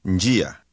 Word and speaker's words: njia: Did you hear the slight N sound at the beginning of this word? – Njia. – Say street. – Njia.